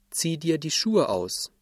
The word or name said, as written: ausziehen